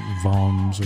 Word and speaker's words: Worms: So you had fly, hook, tackle, the German city is worms. – Is it really? – Yeah. But it's pronounced like Vom's or something like that in German.